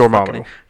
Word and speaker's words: Dormammu: Dormammu.